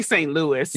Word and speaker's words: St. Louis: I say St. Louis. – Yeah, St. Louis. Okay. – I think movies and musicals have said, you know, old St. Louis, that kind of thing.